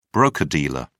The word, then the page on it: broker-dealer: British English